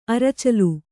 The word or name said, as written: ಅರಚಲು